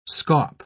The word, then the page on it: scaup: British English